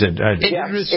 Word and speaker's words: Idris: Idris, you pronounce it Idris? I know it's Idris.